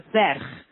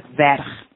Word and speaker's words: ferch: So merch becomes y ferch. The M at the start of merch turns into an F, which is pronounced as a V, y ferch.